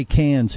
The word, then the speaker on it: Cairns: So it reads or it's spelled like Cairns, but they say Cairns here in Australia. Mike, does that confuse you? Are you okay? – I'm always confused, but it's C-A-I-R-N-S.